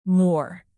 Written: more

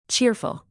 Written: cheerful

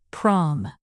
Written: prom

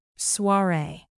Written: soiree

